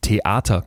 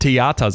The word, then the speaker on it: Theater: Das fand ich interessant, weil sie zum Beispiel sagt, sowas wie StudentInnen, dass man das nicht aussprechen könnte, das wäre Quatsch, weil wer in der Lage ist, Theater korrekt auszusprechen, also mit einem glottalen Verschlusslaut, dieser kurzen Pause vor Arter und nicht Theater sagt, der wird auch dieses StudentInnen, also StudentInnen hinbekommen.